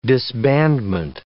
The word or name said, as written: disbandment